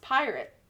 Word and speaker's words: PYRT: This time, he's putting together a service called, it's capital P-Y-R-T, pronounced Pirate, which he calls a virtual, immersive, decentralized reality.